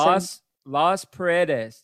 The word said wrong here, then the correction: Las Paredes: did I say it right? – Las Paredes, yes.